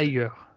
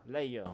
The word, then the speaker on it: Liger: vu que Joshin Liger, j'espère que je le dis bien, moi je le connais pas. – Liger. – Liger, ouais, merci.